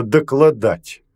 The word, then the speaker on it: докладать: Когда потребуется докладать начальству, он умел. Именно докладать, с ударением на последнее «а».